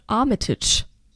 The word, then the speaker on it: Armitage: Nicht nur, dass wir eben wieder in England sind, sondern auch in dieser Serie spielt Richard Armitage. Armitage, ich weiß es nicht. – Ricciardi Armitage. – Eine, ich glaube, du hast beim erstmal richtig ausgesprochen.